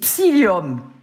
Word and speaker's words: psyllium: C'est psyllium, P-S-Y, psy, oui. – Comme le psy, deux L-I-U-M, psyllium blanc en poudre, j'insiste, en poudre.